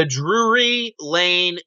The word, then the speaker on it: Drury Lane: Did I say it right? – You said the right, yeah. – Drury.